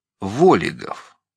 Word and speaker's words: Волигов: Фамилии его по-настоящему Волигов, с ударением на первом О.